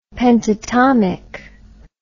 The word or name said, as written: pentatomic